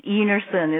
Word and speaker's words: Einarson: My last name is Enerson. It's spelled E-I-N-A-R-S-O-N. And like you, I just say to people, I don't really care how you pronounce it as long as you don't insult me.